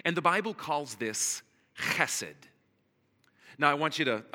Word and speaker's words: chesed: I want to help you pronounce this today. So say esed. Now say, now look at the person next to you and say, and you smash it together and you have chesed.